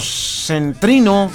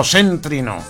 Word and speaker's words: céntrino: Buenos días, un saludo para el conjunto centrino de punteros Salinas de Hidalgo, San Luis Potosí. ¿Es centrino o céntrino? Conjunto céntrino, tiene el acento en la es céntrino.